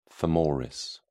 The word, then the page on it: femoris: British English